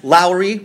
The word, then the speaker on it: Lowry: And we want to thank our contestants, Owen Laukkanen, Jess Lowry, rhymes with flowery, and Jay, the King of Us Stringer.